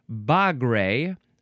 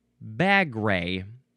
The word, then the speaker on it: bagre: So this is pronounced bagre or bagre, and the British actually looks the same.